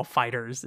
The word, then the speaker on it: FighterZ: I think the Z is not pronounced at the end of Dragon Ball Fighters. It's just a Fighters and like, yeah. Replacing the S, essentially, which actually is pretty exciting for me.